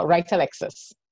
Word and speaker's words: Wright-Alexis: It's now Wright-Alexis, sorry.